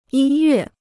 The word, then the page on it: 音乐: yīn yuè